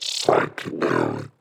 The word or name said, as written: Psychedelic